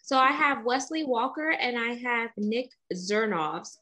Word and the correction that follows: Zervis: and I hope I pronounced his name correctly. – Oh, my God. Yeah, it's Zervis. It's Zervis. All right.